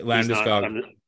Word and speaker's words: Landeskog: Like, you know, you're one of your best players, and you're at Landeskog, is it? Is that how you say his name? – Yeah, Landeskog.